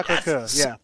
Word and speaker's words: Sacré-Cœur: Better. Sacré-Cœur, yes.